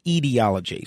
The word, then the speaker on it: etiology: Hey, by the way, I'm told that word is pronounced etiology. So thank you to the gentleman that corrected me on that etiology.